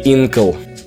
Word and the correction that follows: Инкал: Инкал. Третьего ударения здесь нет.